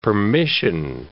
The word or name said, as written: permission